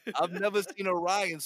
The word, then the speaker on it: Rian: Is that how you pronounce it? – Yeah, it's just Ryan. – Mark, you were saying something different yesterday. How do you say his name? – I thought it was like Rian Johnson. – No, nah, it's Ryan.